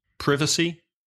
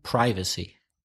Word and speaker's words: privacy: British English, privacy. – American, privacy.